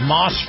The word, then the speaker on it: Maus: This portion of the program is brought to you by our friends at Moss Family Automotive and their brand new dealership, Moss Acura. Now, Moss is spelled M-A-U-S, and Moss Acura is located on North Ford Avenue in Tampa.